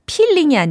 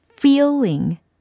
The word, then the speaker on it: feeling: P가 아니라 F 이 소리를 연습하셔야 돼요. P가 아니라 F라고요. 예, 차이 느끼실 수 있죠. 필링이 아니라 feeling, feeling. 내가 입을 꽉 다물고 발음을 하는지 아니면 앞니 두 개가 입술 밖을 튀어나와 있는지 이걸 직접 보시면서 연습하시면 좋습니다.